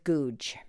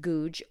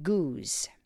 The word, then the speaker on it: Goudge: And then the best for last is the author Elizabeth Googe. Her name is spelled G-O-U-D-G-E. Elizabeth Googe, or in England they pronounce it Goose.